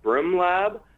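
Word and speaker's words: BRMlab: But from there, we continued on, and we got a really, really fascinating tour at Brim Lab. It's spelled B-R-M Lab.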